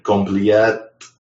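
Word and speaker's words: Compleat: Compliat. – That's how I pronounce it. Like it's in your mind, like that's your inner voice says compliat. – Yeah, just like complete.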